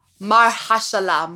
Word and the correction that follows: Mahershala: Marshala. – Oh, Mahershala.